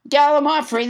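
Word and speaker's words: gallimaufry: Let's never forget Gallimafree, which I pronounced wrong until nobody, James Hyder, corrected me. Gallimafree, that's how you say it.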